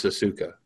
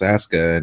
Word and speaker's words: Asuka: You'd say that just automatically, and the U is not pronounced, so it's Asuka, and, you know, I mean, in Tagalog and Filipino, you pronounce the U if you want to, you know, I mean.